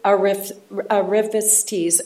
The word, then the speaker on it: arrivistes: Wealthy arrivistes, am I saying that right? – Arrivistes.